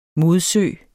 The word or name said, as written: modsø